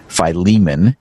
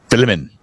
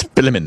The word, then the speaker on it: Philemon: In Bible college, I was taught to pronounce it Philemon. And then I met a Greek Orthodox priest for whom this was his first name. And he said, it's Philemon. It's Philemon.